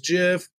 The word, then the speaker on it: GIF: Just call it what it's kind of like, GIF versus JIF. Technically, it's JIF.